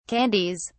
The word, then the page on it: candies: British English